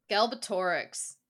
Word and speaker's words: Galbatorix: some mad king called Galbatrix, who lost his dragon. Oh, what? How do we say it? – Galbatorix. – There we go.